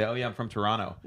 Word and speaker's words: Toronto: The people in Toronto don't pronounce the second T.